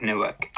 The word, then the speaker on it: Newark: Should we go on to The Many Saints of Newark? Am I saying it right? – Yes. So this Yes!